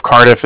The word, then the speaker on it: Cardiff: That's N-I-K-O. And then the Bank of Cardiff is spelled C-A-R-D-I-F-F as in Frank Frank. So it's like car and then different.